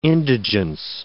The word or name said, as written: indigence